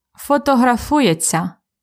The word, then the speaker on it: фотографується: Він фотографується. Фотографується. Так. When we pronounce it fast, we have really just ся at the end.